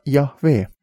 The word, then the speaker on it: Yahweh: Nagu öeldud, uurijad arvavad, et Jumala nime on õigem hääldada Yahweh.